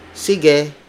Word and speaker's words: sige: You say goodbye or you do your leave takings, you would say like sige, which means okay.